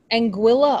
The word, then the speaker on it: Anguilla: Someone told us that Anguilla is pronounced Anguilla.